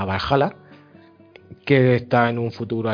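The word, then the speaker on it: VA-11 Hall-A: El siguiente que he jugado es Valhalla. Se escribe VA11 HAL barra A.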